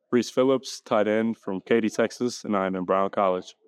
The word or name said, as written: Rhys Phillips